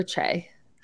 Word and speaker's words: Che: I would love to see Aiden interact with Shay. Or Che. – Shay Mitchell? – Che Diaz. I said Shay first.